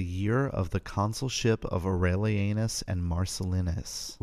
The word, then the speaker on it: Aurelianus: I will try to pronounce it better. Year of the consulship of Aurelianus. Seriously, that's how it's pronounced.